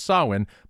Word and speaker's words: Samhain: Don't know how many of you are fans of Glenn Danzig, but he famously led three different bands, The Misfits, Samhain, and Danzig. And yes, I know the correct pronunciation is Samhain, but that's how he pronounced it.